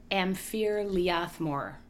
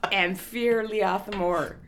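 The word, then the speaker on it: Am Fear Liath Mòr: Amphir Leathmore. – Perfect. That was beautiful. – Okay. I couldn't... That's why I'm your straight man. All right. – That was a beautiful pronunciation. – Amphir Leathmore.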